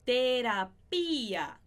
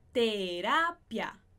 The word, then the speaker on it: terapia: E terapia, a sílaba mais forte é diferente do português. Em português, a gente fala terapia. Em espanhol, a gente fala terapia.